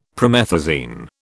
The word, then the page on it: promethazine: British English